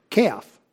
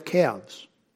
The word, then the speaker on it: calves: So will we render the calves of our lips, and I deliberately pronounce the L, calf. Calf. Bulls and cows have calves.